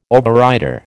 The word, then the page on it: overrider: American English